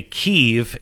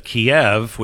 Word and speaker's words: Kyiv: I mean, it's the reason why we say Kyiv instead of Kiev, which we've always said, because Kiev is the way the Russians have pronounced it, if I'm not mistaken, right? – Correct.